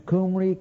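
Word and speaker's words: Cymru: Hence, the correct pronunciation of the country of Wales or the land of the Qimri in its ancient tongue would be as near as possible to the names Qumri, Qumri, Qumri.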